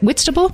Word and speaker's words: Whitstable: Is it Whitstable? – You're saying exactly right, yeah.